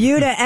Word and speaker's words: Buda: It's called the Buda. – Sounds like Peter Wolf. – It's spelled B-U-D-A, and I looked it up. It is pronounced like beauty.